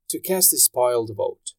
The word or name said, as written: to cast a spoiled vote